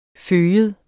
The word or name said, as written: fyge